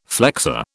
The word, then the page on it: flexor: British English